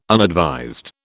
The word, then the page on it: unadvised: British English